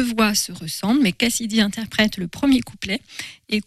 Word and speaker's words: Kcidy: Alors ça s'écrit K-C-I-D-Y, Cassidy,